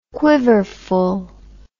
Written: quiverful

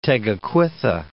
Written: Tegakwitha

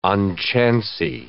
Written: unchancy

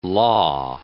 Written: law

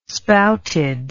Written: spouted

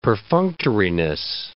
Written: perfunctoriness

